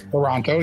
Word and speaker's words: Taranto: So next you are in Taranto. Do I say that right? – Taranto, yep.